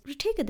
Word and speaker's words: Ritika: how do you pronounce this? Most people said Ritika. And I was like, okay, it is Ritika then.